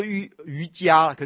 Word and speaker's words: 瑜伽: 静心戒观法是可以学习的，还有呢欲切菩萨戒是可以学习的啊，欲切是六顿的欲切菩萨，其实应该念作瑜伽，可是我师父喜欢念作瑜切，是因为念作瑜伽很容易跟那个瑜伽搞不清楚，我礼拜五曾经讲过，所以只好念成瑜切，他其实不是念瑜切，是念瑜伽实地论，但是呢欲切是这六顿里面呢有一个菩萨戒啊，欲切是六顿的菩萨戒非常重要。